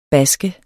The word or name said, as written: basker